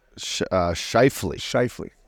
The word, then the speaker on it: Scheifele: Scheifele.